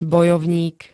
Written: bojovník

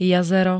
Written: jazero

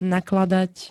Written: nakladať

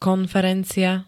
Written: konferencia

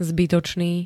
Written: zbytočný

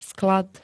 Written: sklad

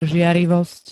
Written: žiarlivosť

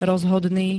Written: rozhodný

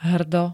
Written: hrdo

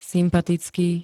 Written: sympatický

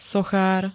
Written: sochar

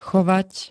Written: chovať

